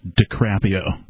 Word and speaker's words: DiCaprio: I'm sorry, as a stickler for pronunciation, it's DiCaprio. – DiCaprio.